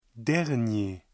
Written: dernier

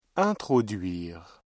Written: introduire

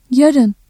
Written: yarın

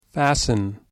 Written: fasten

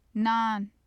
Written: nan

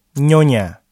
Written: nyonya

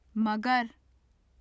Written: magar

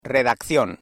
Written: redacción

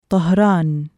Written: طَهْرَانُ

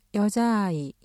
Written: 여자 아이

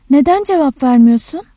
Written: cevap ver-

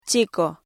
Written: chico

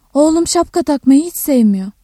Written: sevme-

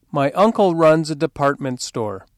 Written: department store

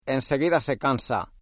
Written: enseguida

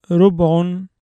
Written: رُبْعٌ (أَرْبَاعٌ)

